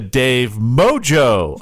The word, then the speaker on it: Mojo: Could you use it as M-O-J-O. – That's right. We've got a big shout out to Dave Mojo, our latest Patreon supporter.